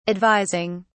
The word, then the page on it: advising: British English